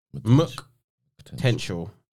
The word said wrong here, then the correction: MK Tential: yeah, or MK. Sorry, it's MK Tential, yeah. – That's how you pronounce it.